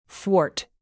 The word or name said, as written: thwart